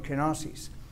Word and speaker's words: kenosis: Es la palabra kenosis, o la pronunciamos así en español, kenosis.